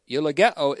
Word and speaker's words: eulogeo: E-U-L-O-G-E, long O, eulogeo is a verb, not an adjective, in Hebrews 7:1, 7:6, and 7:7.